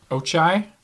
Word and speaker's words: Ochiai: So one town that they named, and I'm going to butcher the fuck out of this, is Ochia? Oh, no, Ochie? – Ochai? – Oh, yeah, O-C-H-I-A-I.